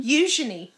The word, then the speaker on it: Eugenie: How do you say her name again? – Eugenie. Very French.